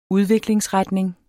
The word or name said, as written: udviklingsretning